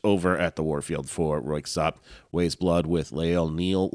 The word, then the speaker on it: Röyksopp: But I did a little research, and I think there's two ways to pronounce it. Royksopp with the K, or you don't pronounce the K.